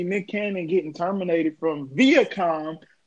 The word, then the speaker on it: Viacom: Okay, so Viacom, we're going to go with Viacom. Viacom is how you pronounce it.